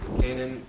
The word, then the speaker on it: Canaan: The whole land of, how do you say it, Canaan? – Yep. – Canaan,